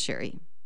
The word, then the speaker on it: Cherrie: Welcome, Sherry. – Thank you. – Sherry spelled with C-H-E-R-R-I-E. Is that right? – Yes. Yep. – But it's pronounced Sherry.